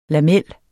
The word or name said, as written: lamel